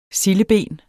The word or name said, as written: sildeben